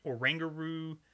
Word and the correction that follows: Oranguru: Or, I'm sorry, let me say it correctly. Oranguru, I'm sorry. It, it, I look at that and I say orangaroo.